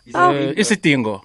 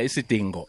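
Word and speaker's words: Isidingo: Isitingo. – Thank you. I just can't pronounce it. – Yeah, Isitingo. – Isitingo. – Isitingo. – I think of the dog, Dingo. Isitingo.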